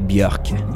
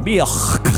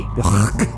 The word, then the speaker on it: Björk: Et au début du bloc, c'était Bachelorette de Björk. – Björk. – Oui. – Björk. Merci. Merci.